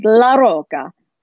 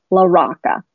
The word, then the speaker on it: LaRocca: Now, my last name is my husband's last name, LaRocca. Now, if you were in Italy, I'm sure you would pronounce it LaRocca, but his family is from Brooklyn. They say LaRocca, like a rock.